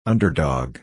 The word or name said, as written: underdog